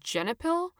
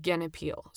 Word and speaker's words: Genepil: The way this is spelled in English looks like it would be pronounced Genepil in English. And sometimes we do have different pronunciations in English than in a native language that are totally acceptable. But I do happen to be able to read Cyrillic, long story. And the way that it's spelled in the Cyrillic alphabet would be pronounced Genepil. So I'm going to go with that.